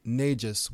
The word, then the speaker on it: Negus: And the title of the king of Abyssinia at that time was called Negus, which is spelled N-E-G-U-S or N-E-J-U-S, different ways.